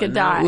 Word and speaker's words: Gadot: I'm pretty sure it's Gadot now. – Keep going back. I was saying Gadot for a while. – Yeah, it's Gadot. Look, it's Gadot. – You know, when I see escargot, I pronounce it escargot usually. – That's French, and she's from Israel. – Good. So if you pronounce it escargot, then you can say Gadot. – Then I'll say Gal Gadot.